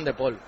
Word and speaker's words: De Paul: En Argentina se pronuncia como se dice, como se escribe, De Paul, como en castellano.